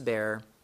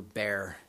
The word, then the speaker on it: Beer: And Jotham ran away and fled, and he went to Beer and dwelt there. Now, in English, it looks like it's beer as in alcohol, but I think you pronounce it in Hebrew, bear, so bear.